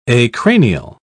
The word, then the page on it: acranial: British English